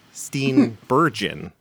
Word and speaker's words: Steenburgen: I have pronounced her name wrong forever. I had to look it up. Steenburgen.